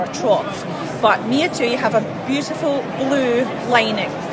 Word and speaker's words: Miatui: So I first got involved with this bag company called Miatui, and it's spelled M-I-A-T-U-I.